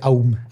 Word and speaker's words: Aum: El sistema de creencias de Om cambiaría, que se, escribe a u um. um. – Um. – se pronuncia OM, pero es AUM.